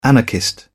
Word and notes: anarchist: British English

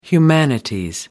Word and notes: humanities: British English

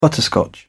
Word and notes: butterscotch: British English

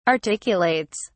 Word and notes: articulates: British English

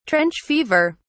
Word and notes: trench fever: British English

